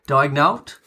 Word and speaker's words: Daigneault: Also, OKC have officially unveiled Mark Daigneault as their new head coach.